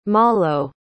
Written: malo

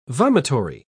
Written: vomitory

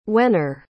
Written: wehner

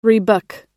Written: rebook

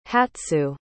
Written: hatsu

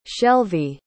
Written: shelvie

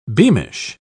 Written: beamish

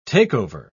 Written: takeover

